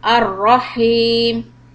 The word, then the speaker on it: الرحيم: So focus on the ra zabar would be ra and the big ha sound at the end. – Ar-Rahim.